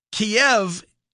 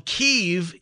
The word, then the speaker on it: Kyiv: Apparently, Kiev is the Russian pronunciation for the city in Ukraine, while Kyiv is the Ukrainian pronunciation for the city in Ukraine.